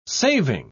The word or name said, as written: saving